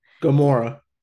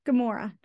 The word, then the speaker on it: Gamora: Star Lord is missing Gamora. Ganorma. – Gamora. – Gamora. Thank you. I'm saying it wrong.